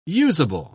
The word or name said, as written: usable